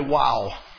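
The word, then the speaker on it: Waw: So what you're looking at here is called a WOW. It is the equivalent in English of our letter W phonetically.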